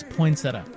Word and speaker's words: poinsettia: Straight from internet, here's how to pronounce poinsettia. – Poinsettia. – I think we've been wrong this whole time.